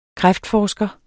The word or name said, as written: kræftforsker